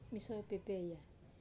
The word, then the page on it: masoi pepeya: wamesa